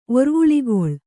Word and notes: ಒರ್ವುಳಿಗೊಳ್: Kannada

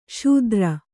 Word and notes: ಶೂದ್ರ: kannada

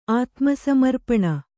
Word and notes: ಆತ್ಮಸಮರ್ಪಣೆ: ātmasamarpaṇe; kannada

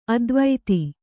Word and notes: ಅತಿವಾದಿ: ativādi

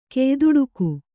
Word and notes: ಕೆಯ್ದುಡುಕು: keyduḍuku

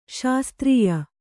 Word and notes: ಶಾಸ್ತ್ರೀಯ: śastrīya

